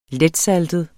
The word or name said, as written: letsaltet